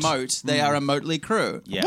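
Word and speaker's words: Mötley: Yeah, but it's actually pronounced Motley.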